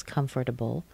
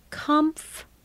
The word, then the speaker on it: comfortable: You'll often hear people say comfortable, comfortable. One, two, three, four syllables, comfortable. But not that often. The common person will say comfortable. Very different from the spelling. Comf, that's the same. But listen to the next one. Ter, the T is before the R, not in the spelling, but in the pronunciation. Comfortable. Comfortable. Are you comfortable? No, I'm uncomfortable. Very, very common. The next word, C-O-L-O-N-E-L, this comes from French, and we've kind of slaughtered it.